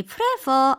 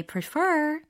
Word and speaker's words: prefer: 이음절 강세예요. I prefer 아니고 I prefer.